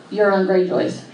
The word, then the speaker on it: Euron Greyjoy: And if she is pregnant, is it Jamie's or could it possibly be Euron Greyjoy's? Is it, did I say that right? – Yeah.